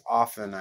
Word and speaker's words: often: I looked it up, and actually both are correct. The first pronunciation, which I guess in a dictionary, the first one is the more used one, is often. – Yeah. – Without the T. But often with the T is also correct.